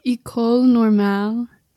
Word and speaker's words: École Normale: École Normale.